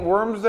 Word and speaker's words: Worms: You think they all ate worms there? Yeah, in Germany there's a town called Worms. Yeah, W-O-R-M-S, but probably pronounced more like Worms.